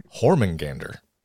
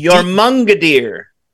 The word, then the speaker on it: Jormungandr: Hormungandr. – That's what they used to call me in high school. Look at that, Hormungandr. He slumbers. – Jormungandr.